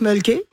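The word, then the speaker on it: Mölkky: Alors, ça s'appelle le mulky. On prononce mulky. – Ouais. – Et c'est M-O-L-K-K-Y.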